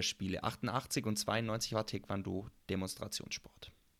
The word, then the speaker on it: Taekwondo: Und die Taekwondo-Technik ist stark eben auf Schnelligkeit und Dynamik ausgelegt und im Taekwondo dominieren Fußtechniken deutlicher als in vergleichbaren Kampfsportarten.